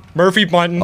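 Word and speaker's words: Murphy Bunting: Sean Mumphrey Birding or whatever, whoever. Jesus. I definitely butchered that. Sorry. – The author? – Murphy Bunton.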